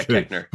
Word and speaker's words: Koechner: David Koechner, am I saying that right? – Yeah, yeah, okay. Yeah, Koechner.